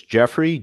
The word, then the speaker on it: Jeofrey: The first name is Jeffrey, J-E-O-F-R-E-Y. Last name is Bean, B-E-A-N, Jeffrey Bean.